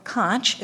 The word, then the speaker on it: conch: plunk down on the middle desk a conch, or conch, if you prefer the alternate pronunciation.